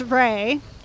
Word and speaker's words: Re: My middle name is Ray. It's spelled R-E, like the musical note, Ray, a Drop of Golden Sun.